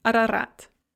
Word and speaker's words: Арарат: So for example, in the names Оренбург or Арарат, both the O and the A there at the beginning of the words are pronounced A, Оренбург, Арарат.